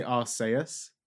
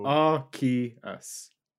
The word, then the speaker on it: Arceus: So in Japanese, they say arseus. – Oh, I don't like that. – Yeah, that's there we go. So ew. – Arceus.